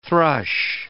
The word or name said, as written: thrush